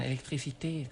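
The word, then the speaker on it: électricité: ภาษาฝรั่งเศสอ่าน electricité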